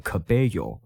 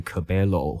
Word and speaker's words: Cabello: 在西班牙语发音会比较类似 Y 或是 J 的声音，所以念 Cabello 而不是 Cabello，或者是，嗯，我也不知道其他人还可以怎么念它。